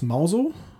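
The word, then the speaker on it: Mousehole: Das befindet sich in der Stadt, die wird geschrieben Mouse Hole, also so wie Mäuseloch. Gesprochen wird es Mauso.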